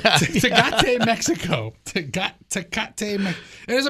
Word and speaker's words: Tecate: border in Tecate. – Well, there you go. – Did I say it right? – There, yeah, absolutely.